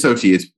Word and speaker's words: Xochitl: My first inclination would be Xochitl. That's not an I at the end. – Xochitl. – Yeah, it is Xochitl. It says it phonetically on Wikipedia.